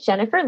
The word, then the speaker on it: Jenipher: So my name is Jennifer Lynn, and I spell it a unique way, J-E-N-I-P-H-E-R.